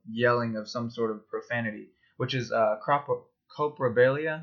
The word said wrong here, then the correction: coprolalia: I believe that's how it's pronounced. – Coprolalia? – Coprolalia. Yeah, that's it.